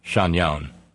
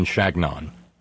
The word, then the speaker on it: Chagnon: It's not. Flavio, it is not Chagnon. It is Napoleon Chagnon, because he was born in Michigan, and that's the way he pronounced it, because his work was taught to me by an anthropologist who knew him, and knew him by name, and had heard him say his name.